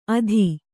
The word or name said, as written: ಅಧಿ